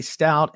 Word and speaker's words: Staudt: So at Matty Stoudt, and my last name is spelled S-T-A-U-D-T.